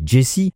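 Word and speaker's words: Djesse: On va enfin pouvoir profiter d'une composition originale issue de son album suivant, le premier d'une série de quatre opus intitulé Jesse, D-J-E-2-S-E, e, qui donne l'impression, quand on le prononce, que l'on lit les initiales de Jacob Collier en anglais, JC.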